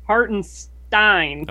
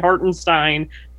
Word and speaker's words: Hartenstein: Hartenstein. – Okay. – Which he got asked, like I said, he got asked on a Zoom how to say it, and he said Hartenstein.